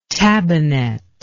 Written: tabinet

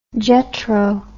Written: JETRO

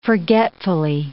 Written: forgetfully

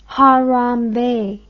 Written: harambee

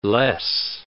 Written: less

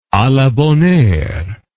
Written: a la bonne heure

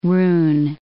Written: rune

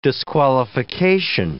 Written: disqualification

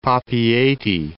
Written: Papeete

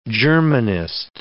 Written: Germanist

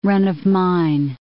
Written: run-of-mine

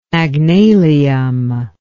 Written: magnalium

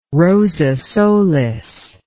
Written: rosa solis

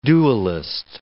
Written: dualist

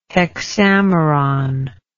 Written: hexameron

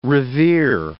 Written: revere

